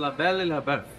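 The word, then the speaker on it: La Belle et Le Bœuf: Am I pronouncing that right? – It's La Belle et Le Bouff.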